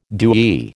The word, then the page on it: dewy: American English